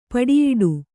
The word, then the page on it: ಪುಡಿಯಿಡು: puḍiyiḍu